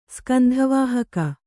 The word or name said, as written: ಸ್ಕಂಧವಾಹಕ